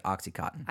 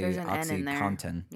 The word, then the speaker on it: OxyContin: Okay, yeah, which, side note, I've always pronounced it OxyContin. – I think most people do. – But it's technically OxyContin.